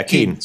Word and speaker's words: Keane: Is it pronounced Keane or Kane? Kane. – Keane. Yeah, Keane.